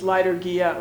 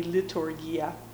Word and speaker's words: liturgia: I think it's liturgia, or it can be liturgia. It's Greek.